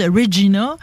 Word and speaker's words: Regina: de la prononciation de Regina qui sonne comme Vagina.